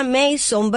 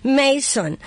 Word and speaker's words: Mason: Se escribe Mason, pero se pronuncia Mason, ¿verdad? Es así, Mason.